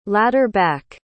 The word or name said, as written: ladder-back